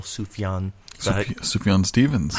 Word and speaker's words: Sufjan Stevens: Who's that guy from Illinois, or at least he has the album in his title, Sufjan Stevens.